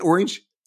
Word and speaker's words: Orange: There's some like NBA, Visa, Orange. Do you pronounce it Orange? – Orange, yeah, indeed.